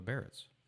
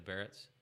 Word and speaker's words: Biarritz: The 60-61, how do you pronounce that? The Brougham? – No, the Biarritz or what? B-I-A-R-R-E-T-C. – The Barretts. – Yeah, the Barretts.